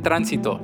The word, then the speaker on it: tránsito: Transit, tránsito.